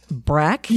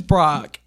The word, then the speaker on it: Brach: So, I thought it was Brach. And then I saw on Charlie Project, which has this archived as well, that it's pronounced like Brach. So, I'm going to go with like Brach from what? Space Ghost, Coast to Coast or whatever. I think that's how it's pronounced.